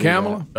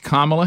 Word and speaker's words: Kamala: By the way, remember early on, stop calling her Kamala. That shows you don't care enough to say her name right. – Mm-hmm. – It's Kamala Harris.